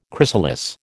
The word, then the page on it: chrysalis: American English